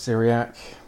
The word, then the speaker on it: Syriac: Syriac. Is that how I pronounce that? – That's how it's pronounced, yep, Syriac.